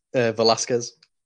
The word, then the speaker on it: Velasquez: Kane Vasquales. I hope I said that right. – Although I much prefer that pronunciation. – No? Ah. – Velasquez. – How do you pronounce it? Velasquez. Kane Velasquez.